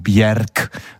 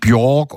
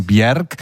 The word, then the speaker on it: Björk: Äh, und das erste ist, also korrekt wird sie tatsächlich ausgesprochen Björk. Also nicht Björk oder so, sondern Björk,